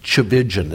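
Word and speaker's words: Tchividjian: And Chavijan is how you say that last name.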